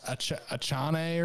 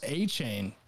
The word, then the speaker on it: Achane: I initially thought it'd be pronounced Achane or something. – Yeah. – It is pronounced Achane.